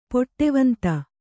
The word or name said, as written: ಪಡವಂದ